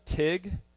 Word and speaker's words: Teague: The locals pronounce it Tig. I have no idea why. It's not spelled Tig, but they pronounce it Tig, so I was trying to, you know, go along with it.